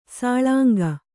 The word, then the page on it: ಸೂಲಂಗಿ: Kannada